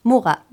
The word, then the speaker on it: Muga: Et je rappelle que Mugha, ça s'écrit M-U-G-A, prononce Mugha.